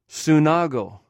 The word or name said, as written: sunagw